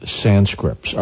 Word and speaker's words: Sanskrit: No, the word is actually, maybe I missed, haven't been pronouncing it quite clearly enough, but it's the word is Sanskrit, S-A-N-S-K-R-I-T, Skrid. – Not with a D in it, so yeah, I apologize for not pronouncing it correctly.